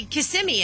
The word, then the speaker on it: Kissimmee: She's the one who's confused me, and I don't know how to say Kissimmee anymore.